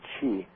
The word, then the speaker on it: Chi: is the Greek letter X, but it's pronounced Chi, and it's literally a cross with a snake tacked on it, uh-huh.